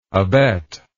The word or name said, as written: abettal